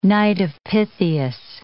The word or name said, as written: Knight of Pythias